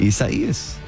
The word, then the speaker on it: Isaias: Isaias? – Yeah, you're getting it. – Is that how you say it? – Isaias.